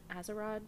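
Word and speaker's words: Atzerodt: So his name was George, I think it's Azarod. – Yeah, that sounds right. – Azarod.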